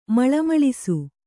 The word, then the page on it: ಮಳಮಳಿಸು: maḷa maḷisu